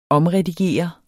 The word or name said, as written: omredigere